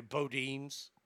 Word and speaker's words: Boudin: Boudin's. I'm sorry, Bodine's, even though it's spelled Boudin. There's no E at the end of it, so it can't really bo- be Bodine's, but that's okay.